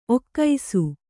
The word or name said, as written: ಒಗಸು